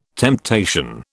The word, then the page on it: temptation: British English